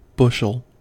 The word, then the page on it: bushel: American English